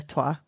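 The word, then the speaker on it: twa: Not as in like de trois, as in it doesn't mean... It's not French. That's not French. It's spelled differently. Okay. T-W-A, I believe it is.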